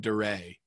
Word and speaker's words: Dare: I would get calls from creditors because this kid that I grew up with, Jutt, his name's Jutt, J-U-T-T. And his last name is Dare, D-A-R-E, but he pronounced it Duray.